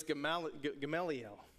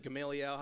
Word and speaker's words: Gamaliel: His name was Gamaliel, or you can pronounce it Gamaliel, however you want to pronounce it.